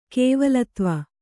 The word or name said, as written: ಕೇವಲತ್ವ